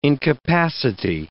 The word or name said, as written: incapacity